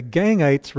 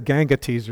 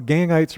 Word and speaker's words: Gangites: And this is actually a picture of, I looked up the pronunciation, it doesn't sound right, it's the Gangites River. That doesn't, but everywhere I see that, I would have said Gangates or something like that.